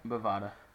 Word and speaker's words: Bovada: Did I say it right this time? I think I did. Bovada? – Bovada.